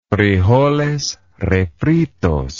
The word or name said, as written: frijoles refritos